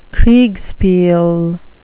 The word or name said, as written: kriegspiel